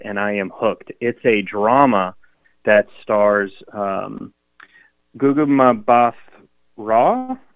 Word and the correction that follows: Gugu Mbatha-Raw: Yeah, I think it's Gugu Mbatha-Raw, but I could be wrong as well.